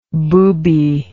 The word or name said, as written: boobyish